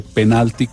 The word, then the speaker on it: penal: Penal eh, con el acento en la última sílaba se escribe sin tilde y entonces su plural es penales.